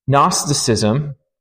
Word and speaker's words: Gnosticism: Gnosticism, and it starts with a G. The G is silent.